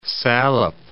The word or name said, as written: Salop